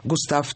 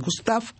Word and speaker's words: Густав: Что знаменательно, он от рождения, этот дед, значит, прадед Кристины, он был Густав Эриксон, наверное, в русской транскрипции Густав, в русском произношении у них Густав. Густав, как вам нравится? Густав. Густав, конечно. Ну, пусть будет Густав.